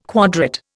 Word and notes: quadrate: Indian

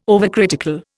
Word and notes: overcritical: Indian

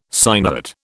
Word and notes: sinuate: British English

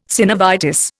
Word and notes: synovitis: Indian

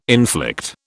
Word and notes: inflict: British English

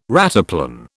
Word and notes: rataplan: British English

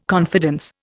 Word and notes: confidence: Indian

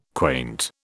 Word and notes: quaint: British English

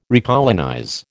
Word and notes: recolonize: American English

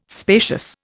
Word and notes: spacious: Indian

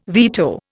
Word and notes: veto: Indian

